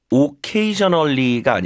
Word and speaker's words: occasionally: Occasionally가 아니라요. K의 강세니까 앞에 음가는 으로 죽습니다. 이걸 슈아 현상이라 불러요. 강세 없는 음절이 으로 약화되는 현상.